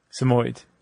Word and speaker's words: Samoyed: Samoyed.